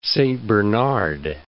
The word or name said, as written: Great Saint Bernard